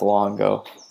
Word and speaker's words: Longo: Longo. L-O-N-G-O.